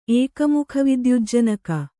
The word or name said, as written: ಏಕಮುಖವಿದ್ಯುಜ್ಜನಕ